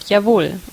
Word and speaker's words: Jawohl: And the word is Jawohl, and you spell it J-A-W-O-H-L, and you pronounce it Jawohl, or you can do a more mockingly, ironically pronunciation, and that would be Jawohl.